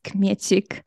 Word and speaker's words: Kmiecik: So my name is Aneta and my surname is Kmiecik. It's a very Polish surname. That's why it sounds like this.